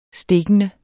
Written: stikkende

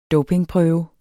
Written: dopingprøve

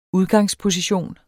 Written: udgangsposition

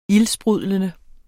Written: ildsprudlende

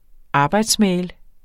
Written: arbejdsmail

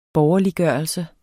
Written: borgerliggørelse

